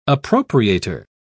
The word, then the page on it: appropriator: British English